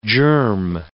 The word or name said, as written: germ